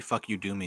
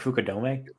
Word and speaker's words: Fukudome: What do you think Kosuke Fuck You Doomy is doing right now? – Wow. – Did you ever notice that his name is basically spelled Fuck You Doomy? Yeah. – Isn't it pronounced like Fukudome? – Fukudome, yeah.